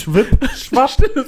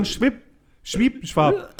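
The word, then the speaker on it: Schwip Schwap: Also, es ist nur ein P, ne? – Was? – Schwipp-Schwapp. – Schwipp? – Ist ja nicht Schwipp-Schwapp. Die Betonung ist ja nicht dann auf der letzten Silbe, also, sondern Schwipp-Schwapp.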